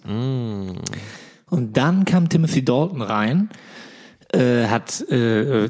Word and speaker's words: Dalton: Ich habe das immer so ausgesprochen wie die Lucky Luke-Brüder. Dalton.